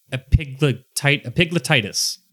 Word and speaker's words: epiglottitis: Like he got a cold and then contracted epiglottitis. That's, I'm going with that pronunciation. – I've never said that word aloud before, but yeah, you're right.